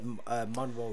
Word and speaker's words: Munro: I pronounce it Munro, like Marilyn Monroe.